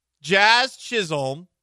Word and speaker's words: Jazz Chisholm: but you also had Chaz Chisholm. Jazz Chisholm.